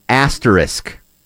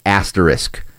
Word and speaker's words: asterisk: Asterisk. Most people, it's supposed to be pronounced asterisk. – Right. – Isk. But most people don't get that isk in there, and they just call it asterisk.